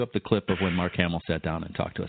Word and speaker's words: Mark Hamill: Mark Hamill, I believe is how it's said.